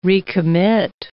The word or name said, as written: recommit